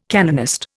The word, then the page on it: canonist: Indian